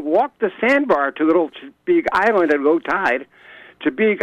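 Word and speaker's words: Chebeague: It's Chibeague Island, Maine.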